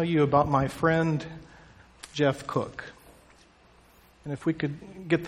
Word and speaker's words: Koch: And his real first name was Gerhard, but people just called him Jeff, and his last name, K-O-C-H, Cook, is the way the family pronounced the name.